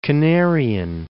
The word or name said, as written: Canarian